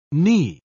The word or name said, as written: Knee